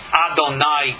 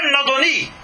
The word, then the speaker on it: Adoni: The same letters, Aleph, Dalet, Nun, Yud, are not pronounced Adonai, God, but Adoni, little vowel difference, and it's Sir.